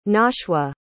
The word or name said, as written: nashwa